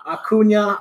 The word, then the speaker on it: Acuña: Ronald, how do you say this his last name? – Acuña.